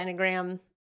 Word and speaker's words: Enneagram: I don't know why she just, oh yeah, she jumps into making a point about the, how do you say it, enneagram? – Enneagram is what I would say.